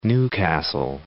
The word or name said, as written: New Castle